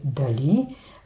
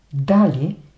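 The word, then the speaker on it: да́ли: Сега, вместо да кажете дали, сложете ударение на първата сричка дали и работата ще се свърши.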